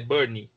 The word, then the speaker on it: Bernie: Eu falei errado da outra vez que eu falei que era Benny, é Bernie, que é o letrista que compõe as canções que o Elton John canta.